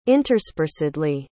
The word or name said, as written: interspersedly